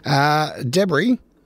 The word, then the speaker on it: debris: So Q-U-I-N-O-A, quinoa, not quinoa, as I was pronouncing it. Quinoa is how it's pronounced. Debris is debris. The S is silent again. Why put it in there? Anyway, I suppose it would be then pronounced debris. But anyway, debris, not debris.